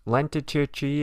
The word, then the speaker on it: Lenticchie: I had the Lenticchie. My boy Memphis at the OG would be sad of my pronunciation. It is Lintichie.